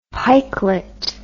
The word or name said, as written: pikelet